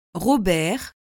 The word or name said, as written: robert